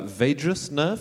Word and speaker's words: vagus nerve: And in all mammals, they branch off the vagus nerve, is that how it's pronounced? Vagus nerve.